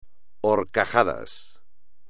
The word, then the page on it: horcajadas: espagnol